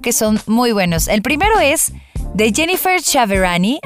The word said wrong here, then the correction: Chiaverini: así se llama, Jennifer Chiaver, Chiaverini, así se escribe, Jennifer Chiaverini,